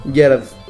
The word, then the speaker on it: Gyarados: Gyarados.